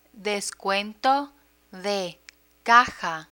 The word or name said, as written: descuento de caja